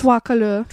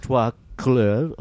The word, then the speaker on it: Trois Couleurs: the Three Colors trilogy, or as they pronounce it in France. – Oh, Trois Couleurs. – Trois Couleurs.